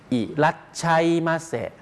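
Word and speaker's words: อิ รั ชั ยม า เสะ (いらっしゃいませ): ีดเส้นใต้แบบนั้นนะครับต้องการแสดงให้ทราบว่ามันจะออกเสียงเร็วนะครับใช่ครับใช่ถ้าออกเสียงช้าๆก็ชะอีครับแต่ว่าขีดเส้นใต้ไว้ให้ทราบว่าคนญี่ปุ่นจะออกเสียงตรงนี้ว่าเร็วนิดนึงนะครับใช่ใช่ครับแล้วก็ตามด้วยมะเสะครับอิรัชัยมาเสะ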